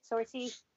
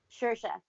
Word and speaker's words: Saoirse: Sorcy? – Saoirse.